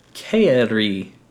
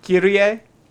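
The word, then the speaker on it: Kairi (χαῖρε): In Greek, the greeting was Kairi, K-A-I-R-E. – Kyrie? – That's not Kyrie. – I don't know how to spell it. It sounded right. – No, Kyrie is K-Y-R-I-E.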